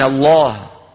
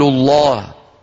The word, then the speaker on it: الله: العرب تفخم بإجماع لام لفظ الجلالة. كلمة الله إن سبقت بفتح أو بضم فإن العرب قاطبة ينطقونها مفخمة، من الله يقول الله، ما أحد يقول من الله يقول الله. انتبهنا؟ إن سبقت بكسرة تبقى على إيه؟ على ترقيق بسم الله، أفي الله شك، قل اللهم.